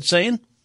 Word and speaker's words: Seine: Is it the Seine, Sin? How do you say it, Ted? Seine? – That's how I say it.